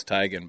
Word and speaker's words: Tiegen: And Tiegen, by the way, is T-I-E-G-E-N, right?